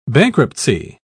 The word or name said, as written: bankruptcy